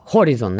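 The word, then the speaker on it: Horizon: Все началось с ошибок в IT-системе под названием э, «Горизон». А, Хоризон, наверное, так э, более правильно, извините меня за мое произношение прекрасное.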